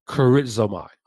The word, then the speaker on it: charizomai: Now, the word eucharistos is actually derived from the Greek verb charizomai, which is spelled C-H-A-R-I-Z-O-M-A-I, charizomai. Mm-hmm.